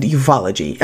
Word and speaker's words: ufology: But what a lot of folks don't know is that you study UFOs and ufology.